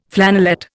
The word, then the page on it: flannelette: Indian